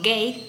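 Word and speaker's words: Gey: llamado Hay o Gay, se pronuncia pero se escribe Hay,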